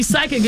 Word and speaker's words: psychic: So you're right, because when we see psychic, it's spelled P-S-Y, right? – I couldn't tell you. – Yes, yeah. – So the P is silent.